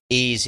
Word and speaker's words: Ys: Is it Ys? – Ys, yeah.